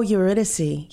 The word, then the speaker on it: Eurydice: You showed me how to pronounce this, but I can't figure out how to, Eurydice? – Oh, Eurydice.